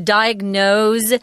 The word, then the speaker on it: diagnose: I wanted to mention that diagnose here is pronounced with a Z, although they spell it with an S.